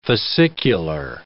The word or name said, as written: fascicular